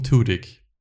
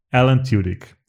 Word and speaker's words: Tudyk: Ich hätte es ausgesprochen Tudyk. – Alan Tudyk.